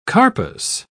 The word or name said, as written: carpus